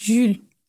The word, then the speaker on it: Jules: Ich glaube, er heißt Jules. – Och Mann, okay, dann war es doch nicht der einzige französische Name, den ich aussprechen kann. Jules Cotard. War das richtig? Okay, wundervoll.